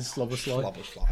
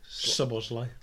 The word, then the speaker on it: Szoboszlai: You said Szoboszlai. – How did you say it then? – Szoboszlai.